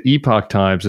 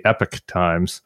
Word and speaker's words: Epoch Times: I always pronounced it the Epoch Times until I wrote a long form article about them and realized they call themselves the Epic Times.